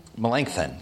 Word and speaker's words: Melanchthon: Melanchthon, yeah.